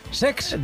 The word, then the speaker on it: Cesc: Lo he dicho mal, es Sex.